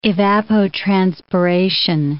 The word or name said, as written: evapotranspiration